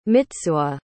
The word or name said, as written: mitsuo